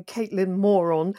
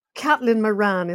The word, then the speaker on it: Caitlin Moran: I remember a couple of years ago, Caitlin Moran, and we've always argued over how you pronounce her name, but I think she'd resist the idea of being Caitlin Moron. I think she's Kathleen Moran is her name.